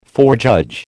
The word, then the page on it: forejudge: American English